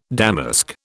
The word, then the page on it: damask: British English